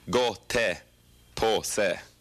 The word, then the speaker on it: gottepåse: So how did I pronounce it? Isn't that right? Go, po, shi. – No. Go, te, po, se.